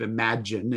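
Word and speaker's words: Imagen: Now, I think it's pronounced imagine, but it's like image with an N on the end of it. But I believe imagine is how we would pronounce this tool.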